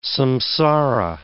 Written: samsara

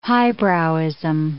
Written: highbrowism

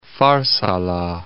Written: Farsala

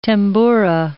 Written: tambura

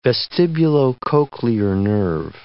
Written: vestibulocochlear nerve

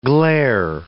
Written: glair

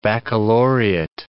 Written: baccalaureate

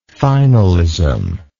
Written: finalistic

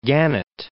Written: Gannett Peak

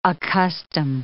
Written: accustom

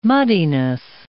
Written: muddiness

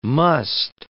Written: musth

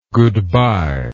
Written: goodby

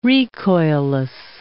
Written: recoilless

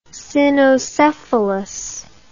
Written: cynocephalus

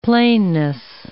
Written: plainness

